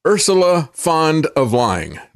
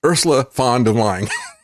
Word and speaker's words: Ursula von der Leyen: And I figured out finally how to pronounce her name. Ursula von der Leyen. That's it. Ursula von der Leyen.